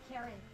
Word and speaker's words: Kari: Carrie. – Spell it. – K-A-R-I. – K-A-R-I? – Uh-huh.